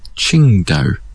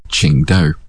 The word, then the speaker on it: Qingdao: Under current pinyin, it's actually Qingdao. But the beer will always be written as Tsingtao because it always has been. But you do actually pronounce it Qingdao.